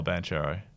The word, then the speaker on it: Banchero: Paul Banchero.